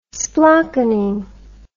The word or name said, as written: splockenin